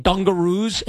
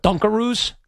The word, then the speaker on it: Dunkaroos: Just like I was saying dungaroos instead of dunkaroos. Nobody corrected me. All these years of saying dungaroos, it's dunkaroos, which actually makes sense because you dunk the roux.